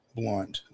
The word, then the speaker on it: Blount: No, not Blount. Blount. The O is silent, I guess.